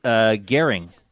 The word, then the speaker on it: Gehring: Steve writes, Gehring, pronounced like herring, not hearing or boring, and Steve is pronounced Worcester, as in Worcestershire.